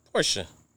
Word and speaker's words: Porsche: So that's why I say Ibiza Porsche is a two-syllable word. – Yeah. Yes. – But from what I understand is it's Porsche. – Porsche if you don't own one, Porsche if you do.